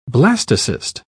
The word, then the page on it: blastocyst: British English